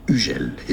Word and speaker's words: Hugel: Hugel is the French pronunciation. U, that's the sound of the letter U. U, very French. The H is silent. And then Hugel, not Hugel. Hugel.